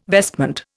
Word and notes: vestment: Indian

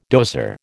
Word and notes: dozer: American English

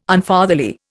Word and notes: Unfatherly: Indian

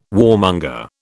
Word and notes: warmonger: British English

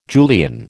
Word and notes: Julian: American English

